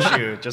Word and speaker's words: Sheu: Brian Shu. Find me there. – And Shu is spelled? – S-H-E-U.